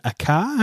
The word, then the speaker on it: Akai: I used an Akai. How do you say that? A-K-I-A-I? – In Australia, I would say Akai.